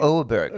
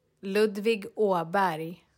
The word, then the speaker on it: Åberg: Åberg. – Right. We should get Melina on and have her pronounce it for us. – Ludvig Åberg.